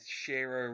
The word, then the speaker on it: Shiro: I'm going to go with Shiro rhyming with hero.